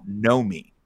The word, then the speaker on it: Nomi: I think Greg was super into this one, but I randomly watched a documentary called You Don't Know Me, spelled N-O-M-I.